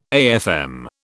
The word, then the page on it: AFM: British English